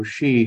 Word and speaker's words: S/HE: I think we should have a caveat to say that he is also she here. I've taken to using S/HE for the pronoun. – How do you pronounce that? – She. Like Scheherazade, you know, she.